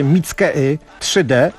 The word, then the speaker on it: Mickey 3D: Co prawda ten francuski wykonawca zwie się Mickey 3D, ale Francuzi wymawiają sobie jak chcą angolskie tpu-tpu imiona, więc po francusku Mickey 3D.